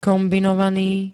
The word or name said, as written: kombinovany